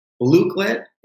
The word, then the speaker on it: Blooket: Blooklet is like the coolest new thing that I've figured out over the past couple of months. It's spelled B-L-O-O-K-E-T.